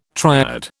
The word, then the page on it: triode: British English